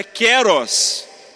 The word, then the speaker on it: kairos: Segunda definição grega de tempo é kairos. Certa vez um amigo fluente em grego, ele é grego de nascimento, me disse: a pronúncia certa é keros.